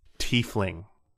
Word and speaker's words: tiefling: Evans wrote this series of novels about a tiefling, because apparently Johannes is going to poop himself if we pronounce it the other way.